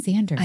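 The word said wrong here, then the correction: Xaden: I know. – It's because it's spelled with an X. And so you're like, okay, Zayden.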